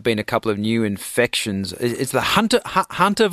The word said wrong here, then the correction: Hanta: virus, am I saying that right? – Hanta. So discovered in the Hantan River in South Korea, so Hanta virus.